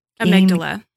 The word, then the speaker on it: amygdala: Amygdala.